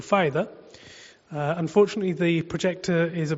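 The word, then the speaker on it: Pfaida: We put a P in the front of our name, and it makes our name a bit weird, Pafaida, but the P is silent.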